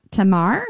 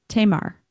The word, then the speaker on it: Tamar: It's Tamar? No. Tamar. – Tamar Ridge. Okay. Tamar Ridge.